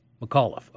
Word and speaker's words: McAuliffe: Forgive me for mispronouncing her name, but she was the teacher who was on the challenge. – McAuliffe. – Okay, yes. – That's how you pronounce it? – Mm-hmm.